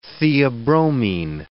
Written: theobromine

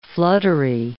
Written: fluttery

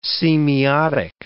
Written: semiotic

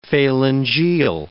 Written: phalangeal

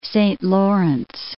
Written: Saint Lawrence

